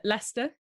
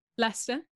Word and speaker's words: Leicester: Leicester. – Wait, how do you say that? – Leicester. – Leicester. Okay.